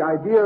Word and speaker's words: idea: I-D-E-A? – Mm-hmm. And why do you pronounce it as though there were an R in it? – Oh, the intrusive R, Your Honor. Oh, well, the BBC use it regularly.